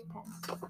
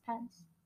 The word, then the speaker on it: Pence: I think it is Pence. – It is? – Yeah, it's Pence.